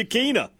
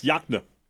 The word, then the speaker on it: Yokena: I'm going to spell it for you, and I want you to tell me how it's pronounced. – Okay. – Y-O-K-E-N-A. – Y-O-K-Yakna? – That's right. You said it correct. I thought you were going to say Yakina. Yes, Yakna.